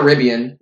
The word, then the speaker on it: Rivian: Rivian or Rivian? – Rivian. – Okay, Riv and then E-I-A.